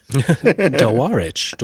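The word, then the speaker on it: Dawarich: And then how do we say it? DeWitch? – DeWarich.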